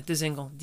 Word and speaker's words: Dzingel: Is that how you say it? Ryan Dzingel. – Yeah, Dzingel. D Z I N G E L.